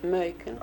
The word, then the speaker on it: Macon: Yeah, it's a city in Georgia, and it rhymes with Bacon. It's Macon.